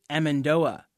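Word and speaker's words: amêndoa: In French, it's pronounced amande. In Portuguese, amendoa.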